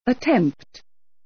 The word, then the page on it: attempt: ə’tempt